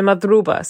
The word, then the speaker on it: madruba: Is it madruba? – Yeah, so madruba.